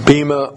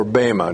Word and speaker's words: Bema: That's the Greek word, and it's pronounced Bema or Bema, depending upon the accent you on the syllable.